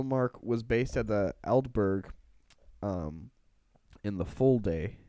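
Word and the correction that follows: Folde: Is that how you say it? – No, Fold. – Fold in the Fold, yeah.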